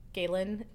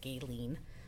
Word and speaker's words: Gaelyn: Galen is not a common name. – No. – very easy to mispronounce. Usually I get Gaylene. I understand it, but it's frustrating.